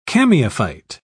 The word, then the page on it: chamaephyte: British English